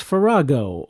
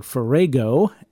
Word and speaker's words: farrago: The word for today is farrago, or farrago, F-A-R-R-A-G-O. With that long A pronunciation, it sounds like something that W.C. Fields might exclaim.